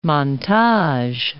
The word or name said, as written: montage